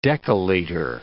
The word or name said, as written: decaliter